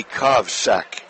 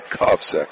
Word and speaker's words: Kovsek: The young lady who came off the bench and played, a 5'7 sophomore, Jackie Kovsek. That is the correct pronunciation. – Kovsek. – Kovsek. Looks like it could be everything but that, but that's how it is pronounced, as I have asked, and we will get that straight in the second half.